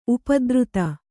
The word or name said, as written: ಉಪದ್ರುತ